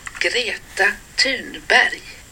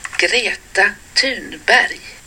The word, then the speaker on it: Greta Thunberg: Greta Thunberg. – One more time. – Greta Thunberg.